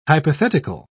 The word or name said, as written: hypothetical